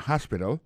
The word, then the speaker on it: hospital: L'hôpital, c'est comme ça, on dit ça en anglais, hospital. Alors, accent tombe sous premier syllabe là, il y a trois syllabes, puis she likes to visit hospital. Ensuite, elle a écrit H-O-S-P-I-T-A-L, hospital, l'hôpital.